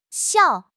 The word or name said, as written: xiao4